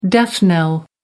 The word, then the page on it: death knell: American English